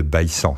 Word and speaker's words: Bessan: il faut dire c'est le domaine de Bessan, c'est comme ça que ça se prononce localement.